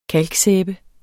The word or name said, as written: kalksæbe